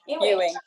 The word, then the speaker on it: Ewing: Ewing.